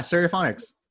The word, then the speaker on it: Stereophonics: Yeah, Stereophonics.